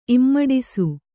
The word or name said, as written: ಇಮ್ಮಡಿಸು